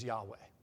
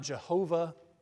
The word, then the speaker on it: Yahweh: But that word we think is the closest we can get is Yahweh or Yahweh. It is not Jehovah.